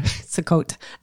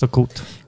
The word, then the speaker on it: Sukkot: Well, it's called in Hebrew, Sukkot.